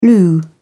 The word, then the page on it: loo: British English